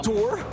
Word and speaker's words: dour: Are you Dour? – Yes. – Frequently mispronounced. – All these years I've been saying Dour, but apparently I've been Dour.